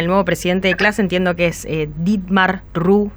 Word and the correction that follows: Ruhe: seguro que lo pronuncié muy mal porque no sé hablar en alemán. – Dietmar Ruhe. – Ruhe, ahí está.